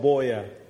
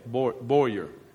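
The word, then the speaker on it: Buea: The city is called Boyer. – Boyer.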